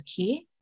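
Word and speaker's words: ki (기): Um, so in Korea, we pronounce it as ki.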